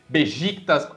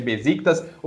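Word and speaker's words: Beşiktaş: É, você fala Fernebate ao invés de falar Fernebate, Bejictas ao invés de Besiktas.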